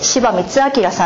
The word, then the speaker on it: 柴光明: で、この方はですね、柴光明さんという方の、こういう字を書いて柴光明さんと読むんですけれども、この方のですね、口伝、口伝えをまとめてですね、本にされてるんですね。